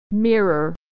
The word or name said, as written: mirror